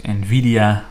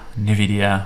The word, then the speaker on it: Nvidia: Ehm, en deze bedrijven zijn Shopify, Roku, The Trade Desk, Nvidia, Nvidia, hoe je het ook wil uitspreken, en Salesforce.